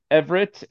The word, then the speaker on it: Everette: So my name is spelled a little funny. Everett is spelled E-V-E-R-E-T-T-E. I got an extra E at the end.